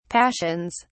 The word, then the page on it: passions: British English